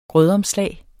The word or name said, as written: grødomslag